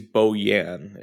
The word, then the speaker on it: Bojan: He's Bojan. It's a soft J.